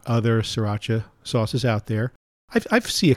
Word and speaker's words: Sriracha: It's actually pronounced Sriracha. – Sriracha. Guess what? No one's going to do that. Neither am I. – Kind of like, it's not a Beamer, it's a Bimmer. – Right. – If you own a BMW. – We're still going to say Sriracha, even though there's an R in there that you don't pronounce, because it's S-R-I-R-A-C-H-A.